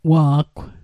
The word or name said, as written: wa'gw